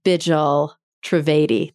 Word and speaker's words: Bijal Trivedi: Bijal Trivedi. Did I say it right? – Perfect.